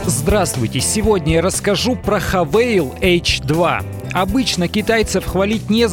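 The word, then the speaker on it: Haval: «Хавейл» — это китайская марка, их крупнейший производитель внедорожников. Пишется как «Хавал».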